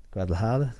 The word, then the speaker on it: Guadalajara: It was a hard night that might have been just as hard night for John Ryder than it was for going to Guadalajara. Guadalajara, did I say that correctly? – Mm-hmm.